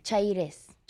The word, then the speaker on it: Chairez: Chairez.